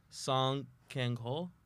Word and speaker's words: Song Kang-ho: Song Kang-ho? – Sure.